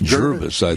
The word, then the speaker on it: Gervais: Is it Gervis? In Oregon, 20 miles north of Salem. – How do you spell it? – G-E-R-V-A-I-S. – Gervis.